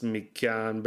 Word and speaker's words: Mi Cyaan: It's Me Can Believe It. Huh. And it's spelled M-I-C-Y-A-A-N Believe It. So it's like written out in patois.